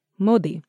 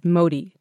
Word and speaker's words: Modi: That's because going forward, I'm going to say my last name with the correct Sanskrit pronunciation. For so long, I have said Modi to make it easier for others to pronounce.